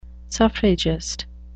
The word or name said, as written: suffragist